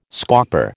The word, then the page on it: scauper: American English